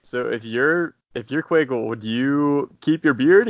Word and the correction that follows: Keuchel: Only if you pronounce his name right. – Yeah, that's true. Keuchel. My bad.